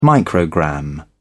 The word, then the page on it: microgram: British English